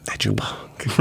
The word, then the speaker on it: Edupunk: Edupunk. – Right. Did I say that right? Edupunk? – You did.